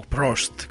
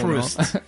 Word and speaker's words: Proust: E questo quindi c'è il discorso dell'omaggio, un omaggio è una frase che comunque è anche a sua volta la citazione di Proust. O Prost, come dobbiamo pronunciarlo? – Prost, a me viene in mente, esatto, il pilota di Formula 1. – Proust.